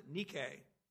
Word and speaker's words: Nike: Of course, you pronounce it in Greek, you pronounce it Nike.